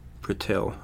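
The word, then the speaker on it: Purtill: Again, Richard Pertell, or Pertill, it's one of those things where you read and you're not quite sure how to pronounce it. – Pertill. – Yes.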